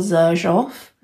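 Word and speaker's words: Xerjoff: They're really old school, incredibly bougie, called Zerjoff. – Spell that for me. – It's with an X. X-E-R-J-O-F-F.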